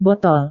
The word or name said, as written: botol